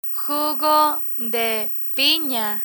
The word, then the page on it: jugo de pina: Spanish